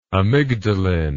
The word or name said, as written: amygdaline